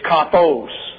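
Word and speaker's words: kathos (καθώς): I said kathos. And that is wrong. It's kathos. You see it right there. That's an omega, not an omicron. So let's do it over. K-A-T-H-O-S. It's a long O in the Greek, kathos.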